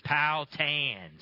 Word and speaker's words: Powhatan: I'm from Ohio. We pronounce it Powhatan. And I'm just saying, but I was with Brother Kurt Scully, and I said, "Yeah, I'm going to be back in Virginia. I'm going to be in Powhatan." He says, "Do not say that. Virginia people get very upset. It's Powhatan."